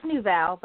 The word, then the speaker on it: Nouvelle: It's Nouvelle, but I like the little spin you put on it.